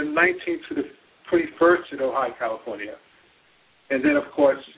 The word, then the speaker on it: Ojai: Is that, am I pronouncing that correct, Dr. Ojai? – Yes. Yeah, that's right.